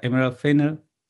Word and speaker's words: Emerald Fennell: Lo interesante de esta madre es que su directora, Emerald Fennell, Sí, ¿no? Así se pronuncia.